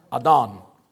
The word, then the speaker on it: Adon: It's the Hebrew word Adon, which Zeb thankfully helped me out and told was not pronounced Adon, because that's what the phonetic thing I found said. So I went to a Hebrew expert before I did this. Adon.